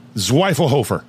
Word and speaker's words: Zwiefelhofer: Zwiefelhofer.